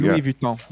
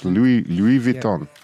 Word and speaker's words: Louis Vuitton: Louis Vuitton. – Yeah. Exactly. Say it like if you were like pinching your lips like Louis Vuitton.